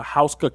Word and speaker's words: Houska: The Hauskull Castle. It's spelled Houska. And it's the Hauskull Castle.